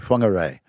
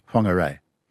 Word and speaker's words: Whangarei: And you find that sound actually in Maori in New Zealand and whangarei. It's a wh. – Yeah, even though it looks like an F, but it's pronounced F.